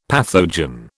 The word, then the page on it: pathogen: British English